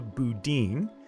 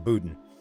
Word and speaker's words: Boudin: It's Chesa Boudin, not Chesa Boudin.